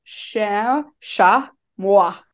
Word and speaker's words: chamois: It literally says sha moi.